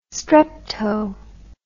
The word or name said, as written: strepto